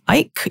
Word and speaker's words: Eich: Brendan Eich. Eich? Yes, that's how he pronounces it. – Okay. – Brendan Eich. – E-Y-C-K or? – E-I-C-H.